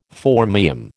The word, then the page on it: phormium: American English